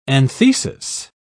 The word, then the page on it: anthesis: British English